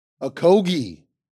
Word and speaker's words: Okogie: Josh Okogie. Did I say it correctly? – You did. You did. Okay. Okogie. Josh, you say it for us. How do you really say it? – Okogie.